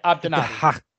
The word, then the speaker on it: Abdennaby: Yeah, Abdennaby. – Hassan. It's got a G at the front, but I'm assuming the G is silent. Hassan Abdennaby, correct? – Correct. I believe so.